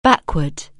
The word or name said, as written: backward